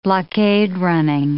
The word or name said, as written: blockade-running